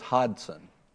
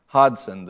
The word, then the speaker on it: Hodson: Candace says Hodson. Hodson, the G is silent.